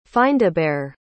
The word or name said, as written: findabair